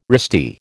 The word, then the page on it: wristy: American English